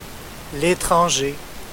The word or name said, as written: Letranger